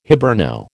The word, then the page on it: Hiberno: American English